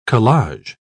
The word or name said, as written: collage